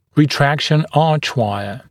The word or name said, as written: retraction archwire